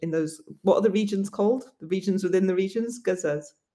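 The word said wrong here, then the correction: gewog: Georgs. – So yeah. It's spelled G-E-W-O-G-S. It's like a crazy spelling, but it's pronounced Georg.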